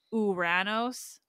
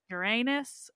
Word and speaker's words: Uranos: I remember that my prof pronounced it Uranos to make it not sound like the planet. Uranus in North America, we like to say that. Uranus works too.